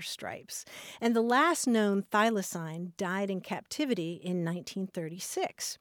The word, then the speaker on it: thylacine: Now, that's an extinct marsupial that's also known as the Tasmanian tiger, and that's spelled T-H-Y-L-A-C-I-N-E. It's pronounced thylacine or thylacine.